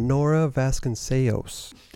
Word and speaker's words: Nora Vasconcellos: We got Nora Vasconcellos. – Did you say it right? – He made the Portuguese proud.